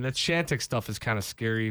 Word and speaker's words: Chantix: He was taking Chantix.